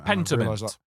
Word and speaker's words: Pentiment: And I said this wrong last time. – Pentiment. – Thank you. I struggled to say that word.